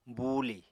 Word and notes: buulì: Apatani

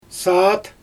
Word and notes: saat: Xyzyl

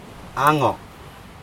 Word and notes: ago: Aneityum

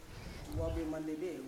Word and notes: wave mandebe: Yawa

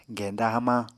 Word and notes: genda hamañ: Apatani

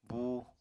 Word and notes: búu: apatani

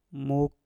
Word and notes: muk: Mpur

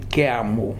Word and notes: Keamu: Futuna; new form

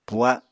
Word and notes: puha: apatani

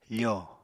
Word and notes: fo: Apatani

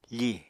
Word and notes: fì: apatani

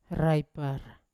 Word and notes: raipar: Yawa

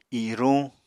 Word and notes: iruñ: apatani

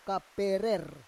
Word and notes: kaperer: Roon

